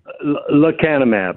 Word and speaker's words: Lecanemab: Lecanemab.